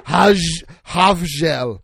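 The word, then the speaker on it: Hafjell: Hofjell. – Hofjell. – Hofjell. Great. That's how you say it in Norwegian, apparently.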